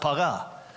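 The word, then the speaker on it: paga (פגע): Now, in the Old Testament, the word for intercession is the word paga. Okay, that's the Hebrew word. You would pronounce it paga.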